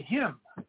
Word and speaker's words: hymn: Because when I spell it H-Y-M-N, I just say him. But then I say, if you say hymnal, now I'm saying the N.